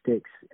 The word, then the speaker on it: Styx: I have a track that's dropping with an artist named Majin, and it's called Sticks, and that's spelled S-T-Y-X.